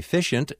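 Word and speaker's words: Physcient: Hugh Crenshaw, Charles Pell are the co-founders of the medical technology company Fishent and inventor of the Kinder Gentler Rib Spreader.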